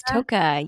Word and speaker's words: Tocca: Toka? – It is. Toka.